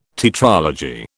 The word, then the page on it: tetralogy: British English